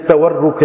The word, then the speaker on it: تورک: بعض تبرک سمجھتے ہیں، برکت والا بات نہیں کریں، اس کا تعلق برکت سے نہیں، تبرک نہیں ہے، یہ تورک ہے واو سے.